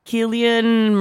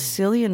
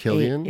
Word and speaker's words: Cillian: I thought it was Cillian Murphy this whole time because it's spelled with a C. – How do you spell it? – C-I-L-L-I-A. – And it's pronounced Killian? – It's pronounced Killian, dude.